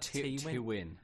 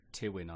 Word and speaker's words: Tywyn: Is it how would you say that? – Tywin. – Tywin, T-Y-W-Y-N. – What did you say? – Tywin, I would say.